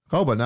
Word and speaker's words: Raubal: Ich weiß das deswegen, weil wir hier zwei Bilder im Haus haben von Geli Raubein. – Raubal, habe ich das richtig gesagt? – Ich denke mal Raubein.